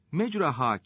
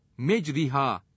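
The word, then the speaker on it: Mecraha (مَجْرَاهَا): Mecraha kelimesinin altında imale yazar. Buradaki ra harfi, üstünden esreye doğru meyillendirilerek okunur. Mecriha diye okunmaz.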